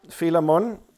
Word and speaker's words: Philemon: Zeg ik dat goed? Ja, Philemon.